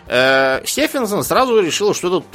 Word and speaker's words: Стефенсон: Я так понял, что он именно Стефенсон, при том, что эта фамилия часто читается как Стивенсон. Но этот вроде как Стефенсон.